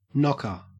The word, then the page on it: knocker: Australian English